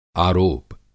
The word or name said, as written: आरोप